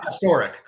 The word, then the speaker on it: Pastorek: Close. Ah. Pastorek. – Pastorek. Okay. – Emphasis on the middle syllable there.